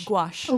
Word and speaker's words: gouache: Wash? – Gouache. – Oh, gouache. Gouache. Goodness. – Actually, the word is G-O-U-C-H-E, and I used to call it gouache. I didn't even know how to say it.